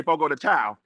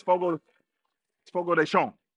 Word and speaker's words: Fogo de Chão: If you're from the South Side, you say Fogo de Chão. No, it's Fogo de Chão. Okay, you got to pronounce it correctly.